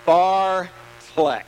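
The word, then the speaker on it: fartlek: It's Barfleck. It's Swedish. Barfleck.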